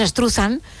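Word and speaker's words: Struzan: Bueno, pues Drew Struthan o como cada uno lo quiera pronunciar, nosotros decimos Struthan. – Struthan, que es más fácil.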